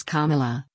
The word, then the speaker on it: Kamala: Scandal star Kerry Washington teaches DNC crowd how to pronounce Kamala, like a comma in a sentence.